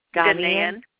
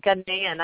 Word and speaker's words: Ghanaian: How do you pronounce that word? – Ghanaian. – Ghanaian.